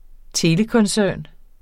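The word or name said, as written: telekoncern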